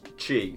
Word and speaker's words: chi: And in the Greek alphabet, the letter X is chi, C-H-I. But... – Is what, sorry? – Chi. – Really? – Yeah. – The sound comes... – But it's pronounced more like the CH in lock and bark. So it's like a...